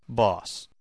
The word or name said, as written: boss